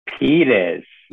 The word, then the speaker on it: Pires: Pyers.